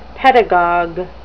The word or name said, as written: pedagogue